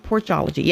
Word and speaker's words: Porchology: Also, Peggy wanted to announce that they're having another Porchology. Did I say that right? Porchology, yes.